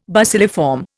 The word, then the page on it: bacilliform: Indian